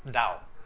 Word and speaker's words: Tao: La palabra china, que traducimos como el camino, es Dao, T-A-O, pronunciado como Dao.